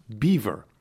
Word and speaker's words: Belvoir: First off is Belvoir, a famous farm and old estate, and it is pronounced Beaver.